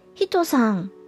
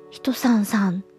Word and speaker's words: 1103: 1103 と書いて、ひとさんとお読みする、ひとさんさんじゃなくてひとさんでお呼びしていいというふうに先日確認をいただいたので、ひとさんと呼ばせていただきます。